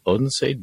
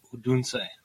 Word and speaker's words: Odense: We are actually on location in Odense, Denmark. And I'm pretty sure I'm not saying the name of the town properly. – Odense. – No, you didn't say it properly either. – Odense.